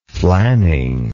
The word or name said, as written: flanning